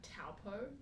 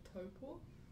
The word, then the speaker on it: Taupo: If we're talking about, like, I don't know, accents, a lot of people say, like, Taupo, which is the name of a town, instead of Taupo, which is, like, the Māori pronunciation, instead of, like, Rere Whakaitu, they'll be, like, Rere Whakaitu, you know, because they're a bit...